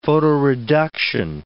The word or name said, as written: photoreduction